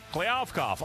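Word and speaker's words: Kliavkoff: This would be George Kliavkov. I think I pronounced that right. – That's the way I heard it. – Kliavkov. Okay, I got it.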